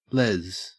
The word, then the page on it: lez: Australian English